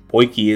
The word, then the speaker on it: potjie: Poiki is spelled P-O-T-J-I-E, and is pronounced poiki.